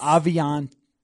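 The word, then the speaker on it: Aviante: It is Aviant. Aviant. Aviant. – Aviant. There's no E. It's a silent E, although... This has been kind of a mess. Another thing from training camp. – Because his college pronunciation says Avion.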